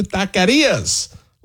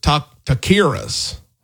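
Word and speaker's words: taquerías: Two Austin spots made Texas Monthly's 25 best new taquerias list. I had a friend of mine from Alabama that used to call it taqueras. We got to work on that pronunciation.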